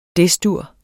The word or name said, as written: Des-dur